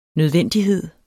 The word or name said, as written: nødvendighed